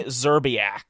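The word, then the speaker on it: Szczerbiak: Just Shazerbeck. Shazerba. – His name is Wally Zerbiak. – Zerbiak, so yeah, the S is silent.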